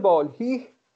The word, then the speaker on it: بالهی: از آنجا سه کس، احمد خوجندی و پسر امیر حسین و احمد بال هنهه هنه، نمیدونم چجوری تلفظ میشه، این چجوری تلفظ میشه؟ – این من بالهی دارم. آها. شما بالهنه دارید، من یه دارم بالهی. – احمد بالهی، متشکرم.